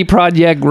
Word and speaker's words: Projekt: All right, and finally, for the folks at CD Projekt Red, they didn't heed the... – Projekt? – That's how they pronounce it.